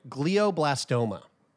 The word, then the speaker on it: glioblastoma: 25 years old, and you have been diagnosed with, and let's see if I can pronounce this right, glioblastoma. – Yes. Good job. That's right.